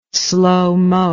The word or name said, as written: slowmo